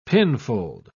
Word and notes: pinfold: British English

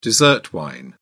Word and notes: dessert wine: British English

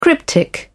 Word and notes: cryptic: British English

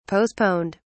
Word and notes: postponed: British English

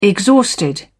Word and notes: exhausted: British English

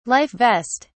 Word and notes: life vest: British English

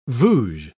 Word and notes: vouge: British English

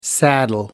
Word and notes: saddle: American English

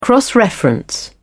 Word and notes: cross-reference: British English

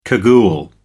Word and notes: cagoule: American English

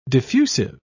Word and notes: diffusive: British English

